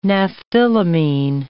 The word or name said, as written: naphthylamine